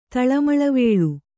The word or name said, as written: ತಳಮಳವೇಳು